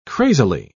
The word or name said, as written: crazily